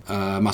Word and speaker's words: Masvidal: Masvidal.